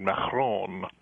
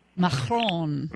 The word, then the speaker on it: Macron: Macron. Macron. Macron.